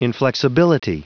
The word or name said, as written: inflexibility